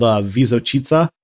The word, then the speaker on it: Visočica: Also, I totally mispronounced the hill's name. It's called Visočica.